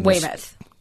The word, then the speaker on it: Weymouth: It's Weymouth.